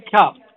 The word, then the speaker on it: Kaf: It's Hey Kuf. – Hey Kaf. Kaf. – Kaf or Kuf? – Kaf, yes.